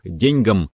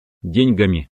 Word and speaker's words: деньгам: Многие на основе этого делают вывод, что при склонении этого важного слова надо делать ударение на первый слог «деньгам», «деньгами», «о деньгах». Но это не так. Это устаревшая норма. В современном русском языке правильно «деньгами», «к деньгам», «о деньгах».